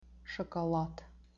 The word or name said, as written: shokolad